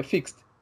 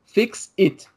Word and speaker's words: fixed: Porque quando você quer falar que você consertou alguma coisa, ou que alguma coisa está consertada, você fala, tipo, fixed com ED no final, mas esse ED tem que ser muito rápido, sabe? Tipo, é fixed. E, tipo, tá consertado, consertado, né? E eu queria falar isso pra ele, cara, só que eu, eu fui pronunciar o ED muito forte e parece que você tá mandando o cara consertar, que parece que fica fix it.